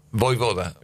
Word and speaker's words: Vojvoda: Vojvoda. – Vojvoda. – Vojvoda. Vojvoda. – Que no. – Sí, sí, porque la J se pronuncia ahí.